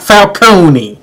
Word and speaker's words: Falcone: Carmine the Roman Falcone, and it's pronounced Falcone, not Falcone. It's Falcone. – The E is silent.